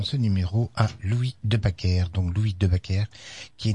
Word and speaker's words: Baecker: Backer, B-A-C-Q-E-R, donc c'est la version française. Tandis qu'en flamand, eh bien, Backer, ça s'écrit B-A-C-Q-E-R. A-E-C-K-E-R. A-E étant donc en langue flamande, le A prolongé. A prolongé, bien sûr, qui est inconnu en langue française. Donc nous allons retenir, bien sûr, l'orthographe flamande de Baker, B-A-E-C-K-E-R.